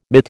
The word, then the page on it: mitt: American English